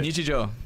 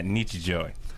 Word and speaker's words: Nichijō: But Nichijō. – Yeah, Nichijō.